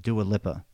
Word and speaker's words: Dua Lipa: The track isn't confirmed, but I read that it was written about his breakup with Dua Lipa, if I pronounce that right.